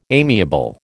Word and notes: amiable: American English

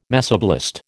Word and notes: mesoblast: American English

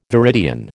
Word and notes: viridian: American English

